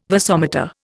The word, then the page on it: vasomotor: Indian